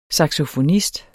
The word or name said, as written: saxofonist